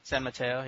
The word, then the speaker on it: San Mateo: He's among them in the how did you pronounce it, dude? The San Mateo County? – San Mateo, yeah.